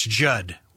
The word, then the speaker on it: Judd: That's Judd with 2 D's. The second D is silent.